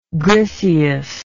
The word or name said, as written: griseous